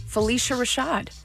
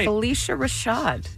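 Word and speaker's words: Phylicia Rashad: Felicia Rashad. – You're saying it right. – Felicia Rashad.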